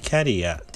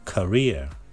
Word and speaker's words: career: ちょっとね、発音が、えー、キャリアではなくて career になりますので、音をね、覚えましょう。